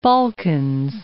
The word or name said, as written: Balkans